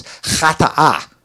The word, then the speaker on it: chata'a: Well, the first one is a difficult one to pronounce because it's got the ch sound, is chata'a.